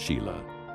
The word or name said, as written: Shelah